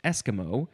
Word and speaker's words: Esquimau: The next word is, well, it looks like it's pronounced Eskimo. The first syllable is emphasized, Eskimo. And yes, you would, it sounds like Eskimo.